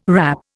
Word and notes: wrap: Indian

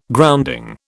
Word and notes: grounding: British English

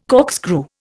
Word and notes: corkscrew: Indian

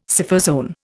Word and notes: Scyphozoan: Indian

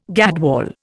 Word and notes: gadwall: Indian

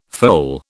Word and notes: foal: British English

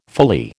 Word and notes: fully: American English

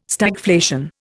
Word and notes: Stagflation: Indian

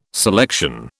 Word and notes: selection: British English

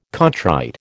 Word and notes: contrite: American English

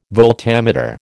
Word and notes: voltameter: American English

